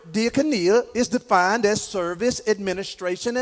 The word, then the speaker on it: diakonia: This word office that Paul used is the Greek word, I'm going to say it like this, diakonia. Or you can say diakonia, all right? D-I-A-K-O-N-I-A.